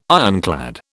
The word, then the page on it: ironclad: British English